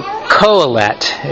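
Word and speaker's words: Qoheleth: You read, Hebrew reads from this way, and this word right here is the Hebrew word that is transliterated Q-O-H-E-L-E-T, or you may see it with a T-H on the end. So Koheleth is the way it's usually pronounced.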